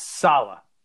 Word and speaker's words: Saleh: How is his name pronounced? – It's Saleh. – Saleh. – Yes.